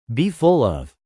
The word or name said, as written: be full of